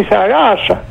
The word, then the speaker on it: θαλάσσα: Αν θέλει να πει, ας πούμε, να πάνε στη θάλασσα, και λέει να πάσει στη θαλάσσα. Τονίζουν το α γιατί έχει μετά δύο σίγμα και είναι θέσιμα κράση, δηλαδή.